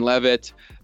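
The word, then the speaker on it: Leavitt: On TikTok, I'm just Ben Levitt. And then on YouTube, it's just Ben Levitt as well. And just to clarify, because my name doesn't sound like it's spelt, it's L-E-A-V-I-T-T.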